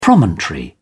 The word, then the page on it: promontory: British English